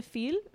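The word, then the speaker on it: Fille: This brand is called, I'm probably going to say it wrong, Ritual de Feel. Feel. It's like feel, but I don't know if it's pronounced fee. Like a silent L-E. – Oh, fee is French for girl.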